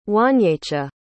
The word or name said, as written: wanyecha